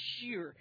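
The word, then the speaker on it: shir (שיר): The first time is shir. You pronounce it shir in the Hebrew.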